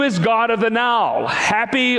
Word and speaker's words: Hapi: That, that plague is often associated with the goddess Happy, H A P I, pronounced as you and I say the word happy, happy.